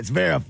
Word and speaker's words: Zverev: Like that, Zverev.